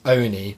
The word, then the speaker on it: Oni: Like, it's not the O-N-I. You say Oni, like Tony.